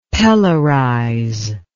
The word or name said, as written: pelorize